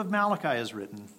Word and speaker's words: Malachi: Mose bis Malachi, right? Is that how you say it? – Yes.